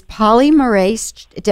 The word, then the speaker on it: polymerase: This polymerase, am I saying that right? Polymerase chain reaction test? – Yes. Yes. Correct.